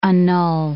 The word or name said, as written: annul